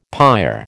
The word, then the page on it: pyre: American English